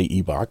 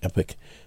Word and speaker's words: Epoch: A school board member familiar with the situation told the Epoch Times, I'll say Epoch because apparently that's how they pronounce it. I like Epic, but anyway, adding that students reported that it was clear from what they saw that Mr. Yates was still fully a man.